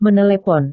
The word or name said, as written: menelepon